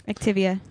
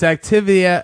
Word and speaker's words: Activia: Activia. – That's pronounced Activia. – Poop yogurt. – I can Cylon wrote it as Activa. – Wrong. – It's Activia.